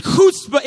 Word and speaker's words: chutzpah: It's kind of spelled like chutzpah but, but it's, it's chutzpah.